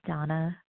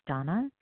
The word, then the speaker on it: Dana: This is Donna B. I spell my name D-A-N-A, but it's pronounced Donna, in Denver, Colorado.